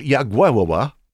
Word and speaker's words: Jagiełło: On this date in church history, King Vladislav Jagiełło, and I do think that's the correct pronunciation, I looked it up, he was the king of Lithuania and was baptized into the Christian faith on this date in church history, February 15, 1386.